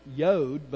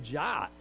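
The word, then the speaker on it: yod (יוד): It actually should be pronounced yod, but back 400 years ago when the King James translators did it, they said jot.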